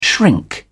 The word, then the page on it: shrink: British English